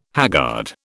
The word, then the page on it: haggard: British English